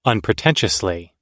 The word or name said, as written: unpretentiously